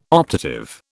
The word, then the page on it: optative: British English